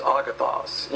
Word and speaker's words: agathos: It should be A-G-A-T-H-O-S, not A-S. It's pronounced agathos.